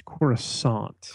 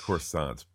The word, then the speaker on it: Coruscant: Correct your pronunciation. It is Coruscant. – Coruscant.